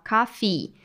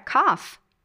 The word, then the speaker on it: coffee: E muita atenção, pois temos que falar esse som do i no final, coffee Pois se eu falar cough, eu estou dizendo tosse ou o verbo tossir, ao invés de café.